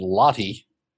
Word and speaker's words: Lottie: And it turns out to be about a young woman, a lifestyle blogger named Lottie, which rhymes with snotty.